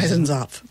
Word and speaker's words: Eisenzopf: There's their son, her brother, played by Rudy Eisenkopf. How do you pronounce Eisenzopf.